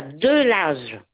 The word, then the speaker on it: Delage: D-E-L-A-G-E. – DELAVE. – Voilà, de l'âge.